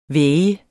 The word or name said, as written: væge